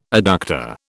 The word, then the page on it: adductor: British English